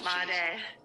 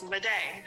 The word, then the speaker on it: Madej: Did I just mispronounce it again? – Oh, Madej. – Madej. – Listen, I've accepted that it's pronounced a different way now. It's Madej.